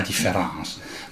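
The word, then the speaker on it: différance: Ci sono altri saggi, in questo testo meraviglioso, tra cui anche il secondo saggio è la Deference, scritto proprio differance con la A invece che la E gioca sul doppio, sullo scarto fra la scrittura e la pronuncia. La pronuncia è la medesima, différence, ma la scrittura è diversa.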